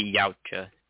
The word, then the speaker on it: Yautja: The Yautja. – That's it. – Yeah. It looks super complicated, and then I heard someone say it, and I was like, it's two syllables?